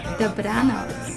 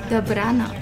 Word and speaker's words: Dobranoc: So tell me, how do you say good night? – Dobranoc. – Say it again. – Dobranoc.